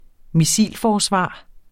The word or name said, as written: missilforsvar